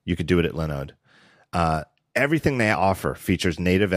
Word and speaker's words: Linode: Looks like Linode, but it's pronounced Linode because they specialize in Linux server hosting.